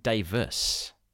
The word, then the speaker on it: Davies: And his name is spelt Davies, D-A-V-I-E-S, but it's always pronounced Davis.